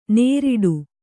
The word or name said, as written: ನೇರಿಡು